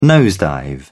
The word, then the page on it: nosedive: British English